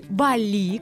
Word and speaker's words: Бали: Или Бали, как говорят местные. – Угу. Вот мы сейчас много обсуждали, куда же все-таки правильно ставить ударение. И в русском языке все-таки на первый слог.